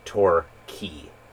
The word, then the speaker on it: Torquay: Our friend Matt in Melbourne had pointed out the correct pronunciation of a town, which was by Bell's Beach, called, it's actually Torquay. It is not Torquay, it is not Torquay, it is Torquay.